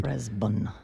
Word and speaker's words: Brisbane: Briz, so you could spell it B-R-E-Z. Brisbane, so that's a schwa sound. – Brisbane. – Stress on the first syllable.